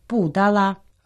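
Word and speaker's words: пу-дала (布达拉): А по-китайски это название произносится «пу-дала». – «Пу» произносится в четвертом тоне, «да» во втором тоне, «ла» в первом тоне.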